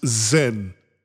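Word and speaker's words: zehn: And that 10 is pronounced Zen.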